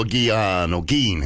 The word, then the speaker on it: Gein: Edward Theodore Gein, it's pronounced, known as the Butcher of Plainfield or the Plainfield Ghoul.